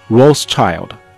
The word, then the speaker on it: Rothschild: Rothschild 啊，罗斯柴尔德。